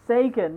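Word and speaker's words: Seguin: So Tyler, I never know how to say his name. Is it Sigwin? Sigwin? – Scheif. Oh, no, Tyler Sagan. – Sagan, that's it.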